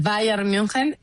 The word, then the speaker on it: Bayern München: A ver, el América está nominado junto con el Barça femenil, uh-huh, junto con el Bayern München.